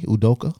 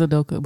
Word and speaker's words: Udoka: Nigerian Udoka, probably. – Ime Udoka. – Spell it. – I've been calling him Ume all week. – IMEUDOK. – Yeah, it is Udoka, but yeah.